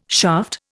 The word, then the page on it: shaft: Indian